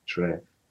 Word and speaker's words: Kyereh: Y ist halt im Ghanaischen oder in, in Sprache Chui wird ausgesprochen und ähm, das muss man eigentlich nur wissen bei dem Namen. Cherry Cheré. Ist, ist schon, geht in die richtige Richtung.